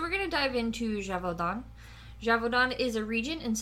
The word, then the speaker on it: Gévaudan: Gévaudan.